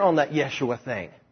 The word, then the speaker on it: Yeshua: And that pronunciation is not Jeshua, it is Yeshua.